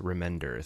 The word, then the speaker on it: Remender: It is in fact Remender.